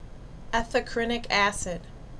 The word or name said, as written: Ethacrynic Acid